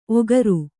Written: ಓಕರ

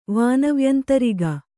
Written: ವಾನವ್ಯಂತರಿಗ

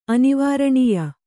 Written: ಅನಿವಾರಣೀಯ

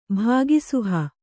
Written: ಭಾಗಿಸುಹ

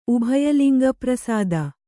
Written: ಉಭಯಲಿಂಗಪ್ರಸಾದ

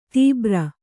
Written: ದುಬಾರಿ

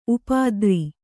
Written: ಉಪದ್ರ

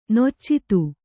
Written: ನಿಚ್ಚೈದೆ

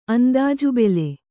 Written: ಅಂದಾಜುಬೆಲೆ

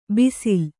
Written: ಬಿಸಿಲ್

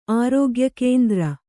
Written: ಆರೋಗ್ಯಕೇಂದ್ರ

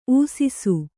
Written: ಊಸಿಸು

